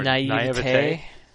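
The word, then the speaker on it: naïveté: But at the same time, I think anything older than that, I find a you know, some neat naivety, or I can never pronounce that word. – Naivete.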